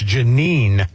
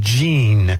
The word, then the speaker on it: Jeanine: I can't ever say her name properly. It's Jeanine. – Yes. – Not Jean.